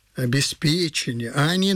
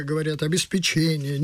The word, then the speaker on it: обеспечение: С ударением на «п» – обеспечение, а не на «че». Чаще с ударением на «че» говорят «обеспечение». Неправильно.